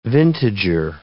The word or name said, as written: vintager